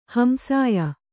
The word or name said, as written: ಹಂಸಾಯ